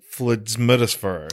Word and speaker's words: FLDSMDFR: But in this one, the acronym is FLDSMDFR or Flidsmidisfer. – Flidsmidisfer, yeah.